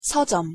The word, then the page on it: 서점: Korean